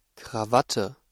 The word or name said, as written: Krawatte